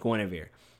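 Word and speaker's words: Guenhwyvar: is named Guinevere, which is crazy because it's not spelled like that.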